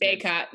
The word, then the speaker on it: Bacot: Backett, I don't know how to say there's Bacot. – Mm-hmm, Bacot. All mm-hmm.